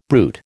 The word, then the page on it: bruit: American English